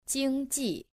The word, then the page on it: 经济: jīngjì